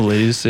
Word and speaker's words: Miletus: One card that I didn't like, probably doesn't synergize as well, but is like an interesting tool, is Daxos of Miletus. I don't know how you say it, but Miletus, yeah.